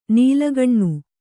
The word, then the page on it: ನಾಲ್ಕನೇ: nālkanē; Kannada